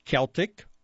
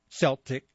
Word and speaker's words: Celtic: Friday and Saturday, March 16 and 17, a Celtic or Celtic celebration. I looked it up. It can be pronounced both ways.